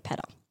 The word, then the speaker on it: Pedder: Pedder. So Lake Pedder? – Pedder. – P-E-D-D-R. – Pedder. Pedder, like pitter-patter, but like pedder.